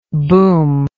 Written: boomingly

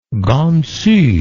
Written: gansu

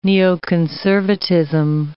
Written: neoconservatism

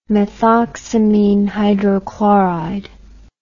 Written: methoxamine hydrochloride

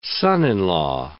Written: son-in-law

